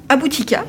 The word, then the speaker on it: Aboutika: À vos côtés, nous avons invité également Reda Aboutika. Bonjour Reda. – Bonjour. Là, c'est bon, j'ai bien prononcé. Oui, c'est parfait.